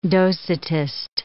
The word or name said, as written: Docetist